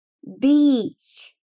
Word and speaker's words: beach: Pensa num dia de sol, sem nuvens, um vento fresquinho, um mar com a temperatura perfeita. Deu um sorrisinho no rosto? Então você já sabe como pronunciar, né? Beach. Beach.